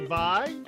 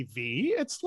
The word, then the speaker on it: Vi: Welcome back to the show, joined by Lady Vi. Don't ever say Lady V, it's Lady Vi. And it's easy, V-I. How hard is that? – People mispronounce it all the time.